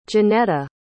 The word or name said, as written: johnetta